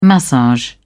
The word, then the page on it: massage: British English